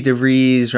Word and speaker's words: DeVries: Back in 2003, Vicky DeVries, Rhymes with Cheese, and I, we did pretty much, you know, the journey up to San Luis Obispo.